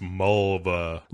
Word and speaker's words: Mulva: Lawrence arrives at the gypsy camp, and he goes to see Maliva. – It's pronounced Mulva. – Lawrence goes to see... wait, it rhymes with what? Vulva? – That's right.